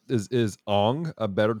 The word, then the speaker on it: Aang: But I will say, as someone who did take a quarter of Japanese in college, the appropriate pronunciation would be Ong.